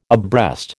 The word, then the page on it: abreast: American English